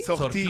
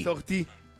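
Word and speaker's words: sortie: ¿Sortí? ¿Sortí? – Sortí. – ¿Cómo se dice? Sortí. – Sortí.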